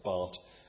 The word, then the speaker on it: Barth: Looks like I can't spell because I spelt Bart without an H. His name is spelt B-A-R-T-H, but it's pronounced Bart.